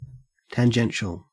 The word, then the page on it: tangential: Australian English